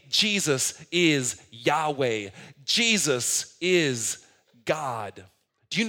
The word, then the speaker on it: Yahweh: In Hebrew, it's pronounced like this, Yahweh.